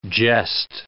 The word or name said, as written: jessed